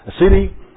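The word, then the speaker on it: aseity: The word Asidi, pronounced Asidi, comes from the Latin prefix a, which means from, and the root c, which means oneself.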